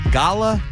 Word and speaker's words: Gala: I don't know how I'd manage to not say any of those, but I think I'd Dan Ball, how would you say it? You're supposed to be like the professional. – Gala. – I would have said gala. – Gala. – Gala. – Like the apple.